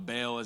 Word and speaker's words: Baal: Now, Baal is actually pronounced Baal, but that's for the Hebrew speakers out there, and we're not going to get into that today.